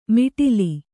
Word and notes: ಮಿಟಿಲಿ: miṭili

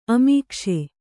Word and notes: ಅಮೀಕ್ಷೆ: amīkṣe

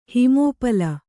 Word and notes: ಹಿಮೋಪಲ: himōpala

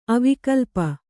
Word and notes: ಅವಿಕಲ್ಪ: kannada

